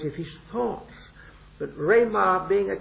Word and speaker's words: rhema: And I forget whether I said yesterday that the word for words is this word rhema. It isn't the ordinary word logos. It's rhema, R-H-E-M-A, and it's a long E, a rhema.